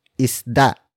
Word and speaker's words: isda: For example, nanghuli ng isda si Cherry Ann. Nanghuli ng isda si Cherry Ann. Nanghuli ng isda si Cherry Ann. It means Cherry Ann caught a fish. Word for word, it's nanghuli. Nang isda si Cherry Ann.